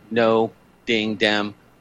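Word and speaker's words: Ngo Dinh Diem: I don't know how you say it. It's NGO, No Ding Dem, who died following a coup linked to the CIA.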